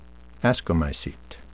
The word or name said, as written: ascomycete